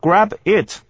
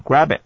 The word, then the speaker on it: grab it: Grab it 是连音的，grab it 啊，这是第一点。然后 IT 的 T 呢是不能发音的，grab it 这样的念法。